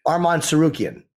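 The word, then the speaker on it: Arman Tsarukyan: Pronounce his latest name for me. – Armand Sarukian. – Sarukian? – Yes. – Say it again. – Armand Sarukian. – Sarukian? – Yeah, yep.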